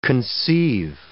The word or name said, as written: conceive